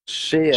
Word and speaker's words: Sheehan: Cheyenne. Wie spricht man den aus? Ich habe den noch nie kommentiert. Sheehan. Sheehan. Sheehan, also.